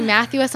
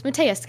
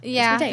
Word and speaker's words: Mateus: She keeps trying to say Matthews, and I'm like, no, it's Mateus, guy. It's Mateus.